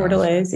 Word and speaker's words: bordelaise: Black garlic bourbon bordelaise. I hope I pronounced that right. I'm not French. Apologize. – Bordelaise, yeah. – Bordelaise. I got it right.